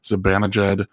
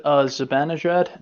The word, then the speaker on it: Zibanejad: I can never pronounce his name. Zibanejad. – Micah Zibanejad? – Yeah, that's it. Zibanejad.